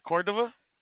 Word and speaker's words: Cordova: My name is Oscar Cordova. Well, I'll spell it to you if you want. C-O-R-D-O and then V as in Victor, A.